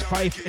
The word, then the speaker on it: Pfeif: The name is Pfeif. It starts with a P, which is silent.